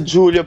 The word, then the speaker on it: Xulio: No, è Giulio, però si scrive Xulio. – Sì, ma si pronuncia Giulio? – Sì. Adè, sì, meno male, sì, si pronuncia Giulio.